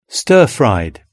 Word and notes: stirfried: British English

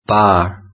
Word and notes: bar: British English

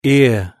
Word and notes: ear: British English

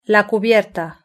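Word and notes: la cubierta: Spanish (Spain)

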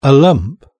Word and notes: a lump: British English

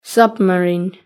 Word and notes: submarine: British English

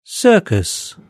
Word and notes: circus: British English

